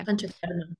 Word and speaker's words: Panchakarma: You mentioned Pachakarma earlier. Am I saying that right? Okay. – Pachakarma, yeah.